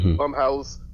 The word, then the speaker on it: Blumhouse: This one is the review of the latest Blumhouse, and I said that properly, Blumhouse horror film, The Purge, The First Purge, The Black Purge, whatever you want to call it.